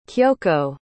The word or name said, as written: kyoko